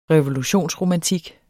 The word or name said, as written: revolutionsromantik